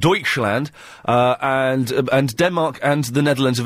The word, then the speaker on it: Deutschland: And you actually said Dutchland, which is Germany, not the Netherlands. – No, that's Deutschland. – Yeah, but it's spelt like D-E-U or however it is.